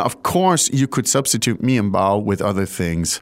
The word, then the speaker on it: 面包: 面包。面包。 – Great. – Fourth and first tone.